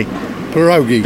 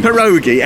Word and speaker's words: pierogi: And we know it's correct to be pronounced. Tell me. – Pierogi. – Pierogi, excellent.